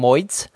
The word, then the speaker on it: moid: actually try to pronounce this, I call them moids.